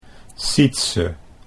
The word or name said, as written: Sietse